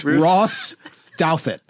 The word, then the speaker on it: Douthat: The amazing thing is you mispronounced Ross. – You know what? I almost yeah. Roos. – Let me try it again. Ross Douthat. – That's right.